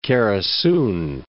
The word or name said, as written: Kerasun